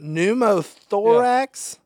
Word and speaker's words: pneumothorax: Pneumothorax? – Yeah.